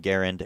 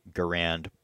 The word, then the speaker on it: Garand: And so the two rifles that were being trialed at the time, the one developed by John Garand, and yeah, that is how he pronounced his name. I know people say Garand, but he pronounced his name with the emphasis on the first syllable, so I will be doing that as well.